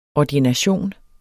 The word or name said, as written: ordination